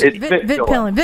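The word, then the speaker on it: Vitpilen: Have you guys seen the new Pit Villains? – I think it's a Vitpillin. – Vitpillin? Am I saying it wrong? – Yeah, it's Vitpillin. – Vitpillin, yes.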